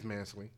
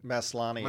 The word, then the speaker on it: Maslany: I think it's Tatiana Mansley? No, I had this the other day. – Yeah, I think it's Maslany. – Let's see. Maslany. – Maslany, yeah. – That's what it is. – Tatiana Maslany, yeah.